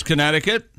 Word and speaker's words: Connecticut: University of Connecticut officials, and their slogan is the middle sea is silent. Welcome to new celebrity to campus in Storrs, Connecticut.